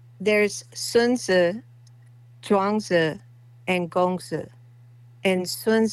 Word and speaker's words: Chuang Tzu: I'll have my wife say it properly. – Chuang Tzu. It's Chuang Tzu.